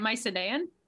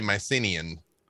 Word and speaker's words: Mycenaean: I think you say it, Mycenaean. – That sounds right. – I say Mycenaean, but but it's another one of those things that I'm like, I've read it.